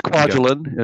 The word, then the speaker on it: Kwajalein: But I don't think it's giving anything away to say that the book largely revolves around Kwajalein. I'm saying that right, right? Kwajalein? – Yeah, yeah. It's Kwajalein. It's Kwajalein. Okay, Kwajalein. Yeah.